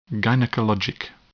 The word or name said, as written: gynecologic